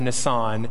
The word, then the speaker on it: Nisan: It says, in the month of Nisan, I pronounced it Nissan for service, it's not the car company, it's the month of Nisan,